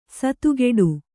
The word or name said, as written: ಸಾತಗಡಿ